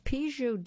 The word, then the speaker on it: Pigoudet: It's the Pigeaud de Premier. I'll spell that P-I-G-O-U-D-E-T, Premier 2022.